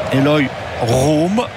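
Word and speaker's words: Eloy Room: Eloy Rome, prononciation forcément néerlandaise, hein, puisqu'on vous l'a dit, les origines sont là-bas.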